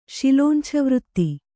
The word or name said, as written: ಶಿಲೋಂಛವೃತ್ತಿ